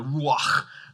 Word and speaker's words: ruach: The breath is the word ruach, or if I said it properly, ruach.